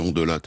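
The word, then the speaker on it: Hondelatte: Comment faut-il prononcer votre nom, Ondelat ou alors Ondelat en trois syllabes? – Ah, je me suis longtemps battu pour qu'on prononce les syllabes, puisque moi je viens du sud-ouest de Bayonne, et donc chez moi on prononce toutes les... Donc on dit Ondelat.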